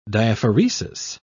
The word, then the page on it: diaphoresis: British English